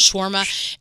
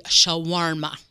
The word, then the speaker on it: shawarma: Because I say shawarma. – Shawarma. – That West Virginia shawarma. And the people that work in the restaurant from Lebanon say shawarma. – Shawarma. – So it's got that extra syllable.